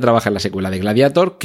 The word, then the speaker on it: Gladiator: que por cierto digo Gladiator y no Gladiator, porque Gladiator es una palabra en latín y como tal hay que pronunciarla. La A en latín es una A. Otra cosa es que en inglés esa A siga diga EI.